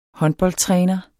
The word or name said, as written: håndboldtræner